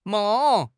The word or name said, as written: meng4